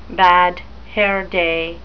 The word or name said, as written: bad hair day